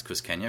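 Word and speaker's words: Cusqueña: I feel like I've just learned how to pronounce Kuskenya because I have to say it every episode last. – I think you finally learned.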